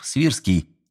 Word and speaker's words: Свирский: Хозяин дома и поручик Станислав Свирский, именно так, с ударением на второй слог, внимательно слушали, время от времени задавая уточняющие вопросы.